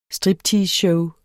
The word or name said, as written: stripteaseshow